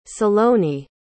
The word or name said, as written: saloni